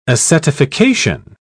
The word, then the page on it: acetification: British English